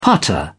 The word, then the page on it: putter: British English